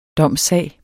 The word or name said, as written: DOMSSAG